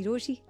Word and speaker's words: RochiE: मेरा नाम है मिर्ची रोशी, नाम की स्पेलिंग है RochiE, and I am the girl always in love with love.